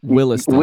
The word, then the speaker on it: Williston: Am I saying that right? Williston? – Williston.